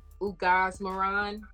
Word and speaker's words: Ugaz-Moran: Um, now, let me see if I can pronounce this correctly. Ugaz Moran. that's perfect. Ugaz Moran. Yes.